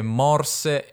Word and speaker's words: morse: Allora, in realtà la O chiusa sarebbe solo su corse, poi bisognerebbe dire morse e storte, ma non so, lui le pronuncia così, non so se è un modo di farle rimare meglio, però questo è il suo modo di pronunciarle.